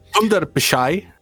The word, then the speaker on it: Sundar Pichai: Sundar Pichai.